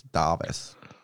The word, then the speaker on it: Davis: It's spelled Davis, but it's Davis.